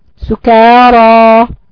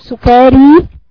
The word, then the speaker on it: سكارى: مش بتنطقي ألف سكارى هل تقول سكاري ولا بتقولي سكارى؟